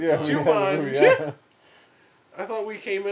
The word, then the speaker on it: Jumanji: Jumanji. That's how you pronounce it.